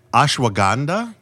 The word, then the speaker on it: ashwagandha: You know, I was just reading something about, and you're going to pronounce it properly, I won't, ashwagandha? – Yes, that's right. – I said it correctly, ashwagandha? Yes.